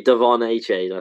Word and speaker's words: Achane: I mean, Devon Achane, I think, is as that you pronounce it. It can be one of those that you can sort of go multiple different ways on.